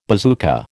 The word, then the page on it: bazooka: American English